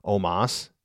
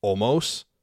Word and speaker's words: Omos: Am I mispronouncing his name by saying Omas, even though it's actually supposed to uh, phonetically spelled Omos, right?